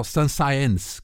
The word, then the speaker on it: Saint-Saëns: E tem, nomeadamente, esta tarantela que dá o nome ao trio e dá o nome a uma peça de Camille Saint-Saëns, portanto, ou Saint-Saëns, como se deve dizer, que o E tem uma trema, é melhor pronunciar o E como deve ser.